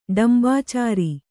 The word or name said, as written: ಡಂಭಾಚಾರ